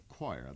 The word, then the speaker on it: Quire: A group known as the Choir. That's spelled, by the way, Q-U-I-R-E.